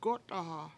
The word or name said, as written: gO7taha7